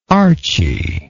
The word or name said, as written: archy